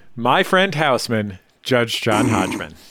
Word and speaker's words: Hodgman: The Judge John Judgman is the most common mispronunciation of the admittedly hard to pronounce show title.